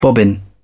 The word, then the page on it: bobbin: British English